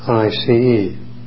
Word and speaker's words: Košice: Then for 4 days I stayed first with my own Jesuits in the capital city, and also the head of the diocese, which is pronounced Košice, Košice.